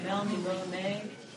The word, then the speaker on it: Melniboné: Wouldn't Joe say Melniboné? – Maybe. – Yeah, it has a diuresis over the E. I think you should.